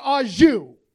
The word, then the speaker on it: au jus: A French dip is so luxurious, that shit comes with its own dipping sauce, and that dipping sauce is pronounced au jus.